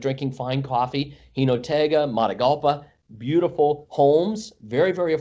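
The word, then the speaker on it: Jinotega: Gino Tega. I'm sure I'm pronouncing it wrong. – Hinotega.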